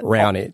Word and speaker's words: Raonic: How do you say that? – Raonic. – Yeah, good stuff.